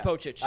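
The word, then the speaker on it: Pocic: Is that the proper pronunciation? Because I have no idea. – I think it is. – It is Pochich.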